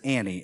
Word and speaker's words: ani: and it is spelled H-I-N-E-H, which literally means behold or look. And then the second root is ani, A-N-I, which is I.